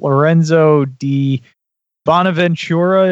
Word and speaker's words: Lorenzo Di Bonaventura: So, and the next article we have, which I'm super excited about, Lorenzo Di Bonaventura, am I saying that right? – Yes. – Yeah. – Sounds good.